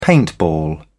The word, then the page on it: paintball: British English